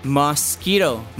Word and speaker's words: mosquito: 那第二个字呢，就是 mosquito.那 mo 发音为 mosquito, 然后的 q 我们的发音是 k, 那 mosquito 的话有三个音节，就变 mosquito, 所以你听到那个 q 越跟 k, mosquito,